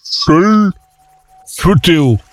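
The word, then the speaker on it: Skellefteå: SK avec voyelle, c'est comme un SH avec la langue derrière. Chou. Le A. Et le A, avec ce rond, se prononce O, plus ou moins. – Chel, Futéo.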